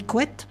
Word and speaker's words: Couette: Une femme militante acharnée pour l'égalité des salaires, Marie Couette, Couette comme une couette, comme ça se prononce,